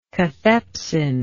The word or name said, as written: catheptic